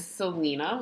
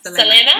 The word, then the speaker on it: Selena: And so that brings up our movie of the day is Selena, which I think I'm mispronouncing based on the way you just said it. – You are. – Selena? – Selena? Yeah.